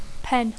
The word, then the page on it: pen: English